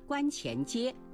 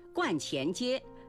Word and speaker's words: 观前街: 年轻的时候到苏州旅游，去了一个繁华的地段，当时我叫它观前街。若干年以后才知道，观是个多音字，还可以读去声观，指道教的庙宇，如道观、白云观。观前街是苏州的百年商业老街，因玄妙观而得名，是集购物、美食、文化于一体的核心商圈，也是游客来苏州的必打卡之地。在这里，观不能读成观。